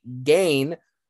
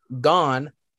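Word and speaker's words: Gane: I would have pronounced it gain, but he actually put parentheses pronounced gone, shock the world. – Well, I don't know if I'd say shock the world, because technically gone is the favorite in this one, but I mean, I don't exactly know.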